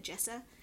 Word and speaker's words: Jessa: Jessa? – Yeah.